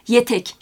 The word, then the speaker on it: etek: Etek aslında yazımda E harfini biz orada görüyoruz ancak telaffuzda yetek şeklinde bir telaffuzla karşılaşıyoruz.